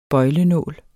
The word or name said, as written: bøjlenål